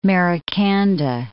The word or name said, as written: Maracanda